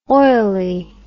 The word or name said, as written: oilie